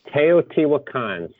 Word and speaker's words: Teotihuacan: Teotihuacan, City of God.